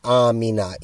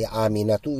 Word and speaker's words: Amina: Where is Amina? In English, we don't really pronounce the last letter. We just say Amina. Even in Arabic, when we speak Arabic, we don't say Aminatu, we just say Amina.